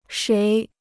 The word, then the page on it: 谁: shéi